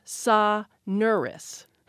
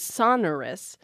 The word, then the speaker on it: sonorous: You know, I would put it, if I were writing a pronouncer for this, for a host, I would put it on the second syllable. – And I think that's where I put it, is sonorous.